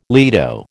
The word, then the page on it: lido: American English